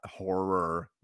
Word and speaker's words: horror: Whereas, like, if you are like, I'm not a big horror guy. Horror. Let's get that that extra R in there. – Yeah. – I'm not two syllables. That's a two syllable word.